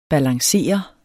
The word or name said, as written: balancere